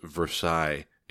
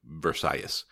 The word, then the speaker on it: Versalles: A little bit of controversy there about whether it should be pronounced Versailles in English. I'm sticking with Versalles.